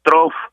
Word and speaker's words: trough: Una de ellas prácticamente que se presentó en la segunda quincena del mes de septiembre fue un trof o bien hondonada casi estacionaria en el Atlántico, así se pronuncia en español, hondonada casi estacionaria, trough en inglés.